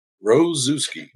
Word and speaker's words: Rozewski: Rozewski.